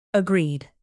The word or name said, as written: agreed